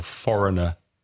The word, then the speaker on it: foreign: But extranjero, foreign or foreigner. Be careful with the spelling of foreign. F-O-R-E-I-G-N. Pronunciation, foreign.